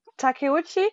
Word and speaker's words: Takeuchi: Also ich habe es hier gefunden, die Schöpferin Naoko Takeuchi, oder wie spricht man das aus? – Takeuchi